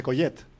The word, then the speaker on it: Collet: pero no sé por qué pronunciamos Collet. Yo también he cometido el error. – ¿Cómo se dice Collet? – Collet, porque es catalán, es de San Iscla sí. de Vallalta, que está al lado de Canet.